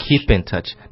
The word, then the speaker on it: keep in touch: Keep in touch. 你可以读成 keep in touch. – OK, 但是你要把这个 p 的这个音呢读成 b 的话会更好，就 b b 的这个音叫 keep in touch.